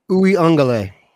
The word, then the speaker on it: Uiagalelei: How do you say it? Umiazolu? Is that? – Uiagalelei.